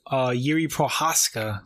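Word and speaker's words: Jiří Procházka: I'm pronouncing the man's name correctly. Jiří Prochazka, because I was like corrected. I was calling him Jiří Prochazka. It's like I knew it was Jiří.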